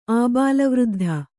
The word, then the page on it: ಆಬಾಲವೃದ್ಧ: Kannada